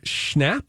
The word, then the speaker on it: Schnapp: So anyway, Noah Schnapp? – Schnapp works. – It's spelled like schnapps, right? – Yes.